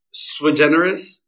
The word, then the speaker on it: Sui Generis: Yeah, so it's Sui Generis.